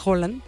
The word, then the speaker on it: Haaland: Vamos a ver ahora, porque ahora somos Haaland. Que, oye, ¿sí sabían que el nombre de Haaland en realidad se pronuncia Holland? Porque la doble A en Noruega, supuestamente, se pronuncia como O, nada más que le decimos Haaland por la canción de Haaland.